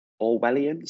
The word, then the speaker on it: Orwellian: leading critics to level accusations of Orwellian surveillance tactics.